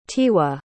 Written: tiwa

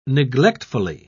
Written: neglectfully

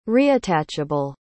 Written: reattachable

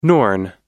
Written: Norn

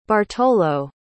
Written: bartolo

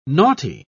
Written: naughty

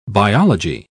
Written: biology

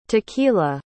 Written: tequilla

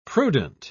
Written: prudent

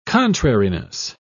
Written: contrariness

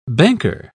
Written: banker

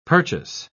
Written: purchase

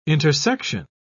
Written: intersection

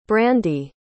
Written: brandi

